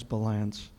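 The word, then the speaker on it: Ballance: My name is Dennis Balance.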